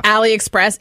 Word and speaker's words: AliExpress: Ali, A-L-L-Y, Express? Or how do you spell that? – AliExpress. It's A-L-I-E-E-X. No, it's A-L-I-E-X-P-R-E-S-S.